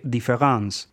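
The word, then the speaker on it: différance: Aquí es donde entra en juego el neologismo concebido por Derrida, que lleva el nombre de différence, palabra que se pronuncia igual que différence en francés, que significa diferencia, pero se diferencia, valga la redundancia, en una letra.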